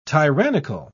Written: tyrannical

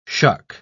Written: shuck

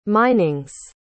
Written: minings

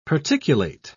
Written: particulate

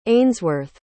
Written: ainsworth

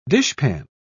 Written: dishpan

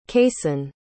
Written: casen

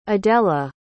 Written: adella

